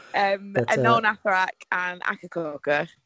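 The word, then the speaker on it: Akercocke: Oh, is there an extra syllable in there? I had no idea. – There's an E on the end of it, which I don't know. Everyone I know pronounces it as Echococker, so I feel like...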